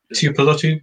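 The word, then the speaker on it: Tuipulotu: And then we've also got Sione Tiopolutu. Have I pronounced that right? – Easy for you to say. Yep. – Tiopolutu, yeah.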